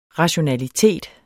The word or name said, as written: rationalitet